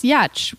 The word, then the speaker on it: Giertz: It's actually pronounced Yetch, which makes no sense, I think, in any language. I've been trying to find the language in which that's okay.